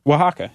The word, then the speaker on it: Oaxaca: O-A-X-A-C-A, like Mexican, Oaxaca, right? – Oaxaca. – Yeah, Oaxaca, mm-hmm. Which I only recently discovered was pronounced Oaxaca. I thought it was like Oaxaca or something. – Yeah, this Oaxaca? – Oaxaca, right? But it's spelled starting with O.